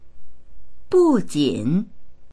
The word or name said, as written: bu4jin3